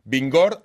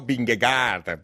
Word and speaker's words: Vingegaard: Atención, porque esto me lo han enseñado a pronunciar. Vingegaard, aunque lo leamos escrito Vingegaard, para que nadie se confunda.